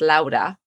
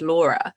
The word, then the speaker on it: Laura: My name in Spanish is pronounced Laura and it exists in English. It's just Laura.